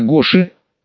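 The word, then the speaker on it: Гоша: Последние две недели именно на Гоше, ударение на второй слог,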